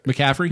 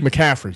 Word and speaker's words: McCaffrey: McCaffrey. – McCaffrey.